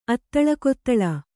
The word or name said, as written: ಅತ್ತಳಕೊತ್ತಳ